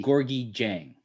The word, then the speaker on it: Gorgui Dieng: Gorgui, did I pronounce that right? Gorgui Dieng, right? – Yeah. – Yeah, it was pretty good though.